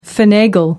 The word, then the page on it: finagle: American English